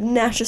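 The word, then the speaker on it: Gnasher: It's Dennis the Menace and Nasher, but Nasher spelled with a G.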